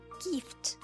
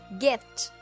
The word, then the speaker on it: gift: 注意在这里 gift 不要读成 gift。有的小朋友说 I want a gift from Santa，一定要注意短音 it 的发音 it gift gift 而不是 gift。